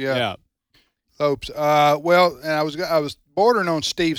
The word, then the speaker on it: Lopes: It wasn't actually Lopez. – He's a Hispanic guy. – Yeah, it was Lopes, L-O-P-E-S.